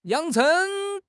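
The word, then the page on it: yang3 cheng2: tones 3, 2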